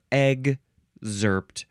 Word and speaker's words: excerpt: Let's see, you can say excerpt, excerpt, with a G and a Z sound, excerpt, excerpt, or egg-zerpt. Emphasize either syllable. It is spelled E-X-C-E-R-P-T, first form. I think I like to say excerpt, with a K and an S sound.